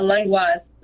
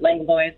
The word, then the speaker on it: Langlois: Uh, Langlois is the proper pronunciation, but Langlois is fine as well.